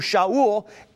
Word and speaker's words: Shaul: As a Roman citizen, Paul had both a Hebrew name that we call Saul, or it's pronounced in Hebrew, Shaul, and he had a Roman name, Paul.